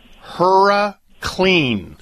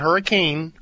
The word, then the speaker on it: HurraClean: There is a product called Hurra Clean. Hurra Clean. Not Hurricane. Hurra Clean. – Can you spell that? – H-U-R-R-A-C-L-E-A-N. Hurra Clean.